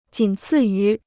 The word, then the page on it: 仅次于: jǐn cì yú